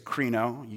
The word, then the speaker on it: krino: Well, the word in Greek, it's krino. You couldn't be in a sermon without hearing something in Greek. So let's try it, saying it together.